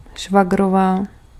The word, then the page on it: käly: /'kæ.ly/